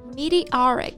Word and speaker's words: meteor: Right, meteoric, it means achieving success very quickly.像我们常说的一举成名，就可以说 a meteoric rise to fame, 或者说一项迅速成功的事业 a meteoric career. – 其实 meteoric 就是 meteor, 流星的形容词形式嘛，那像流星一样的肯定就是非常迅速的，非常飞快的。 – 是的，但是要注意啊，它从名词变成形容词，读音也发生了变化。那名词流星我们读的是 meteor, 重音在第一个音节，而形容词 meteoric 它的重音就到了第三个音节上了， meteoric.